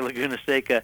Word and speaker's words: Laguna Seca: Laguna Seca. Did I say that right? – Yep, nailed it. WeatherTech Raceway Laguna Seca, our 2.238 mile, 11-turn track that's been here since 1957.